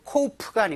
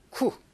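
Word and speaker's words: coup: 이건 코우프가 아니고요. 쿠. 네, 쿠라고 읽습니다. 피 발음이 되지 않고요. 우리가 군사 쿠데타라고 할 때 영어로는 쿠라고 적고 있습니다.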